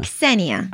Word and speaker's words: Ksenia: Ksenia.